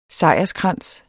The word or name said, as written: sejrskrans